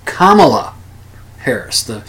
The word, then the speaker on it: Kamala Harris: Anyway, I called her Kamala Harris. Kamala Harris. When it's Kamala Harris, the emphasis is on the first syllable, not the second.